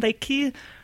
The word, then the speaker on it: Lacke: That's Susan Lacke. My last name is spelled L-A-C-K-E.